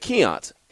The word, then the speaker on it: Keont: He's like, I know how it's spelled, but how do you actually say it? And I said, Keont. And so he said, Keont. Okay, that makes sense.